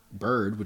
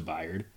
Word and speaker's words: Byard: I mean, it makes sense because you said Bird, which is close to Byard. – Oh. Okay. And like, didn't Bird spell it like B-Y-R-D? – Yeah, it was a Y for him.